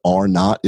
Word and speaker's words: R-naught: It was an analysis that showed if the nation continues to grow at this rate in the effective amount, what R-naught is in epidemiologic terms, it's written as R0, so you may see it as that, or it's pronounced as R-naught.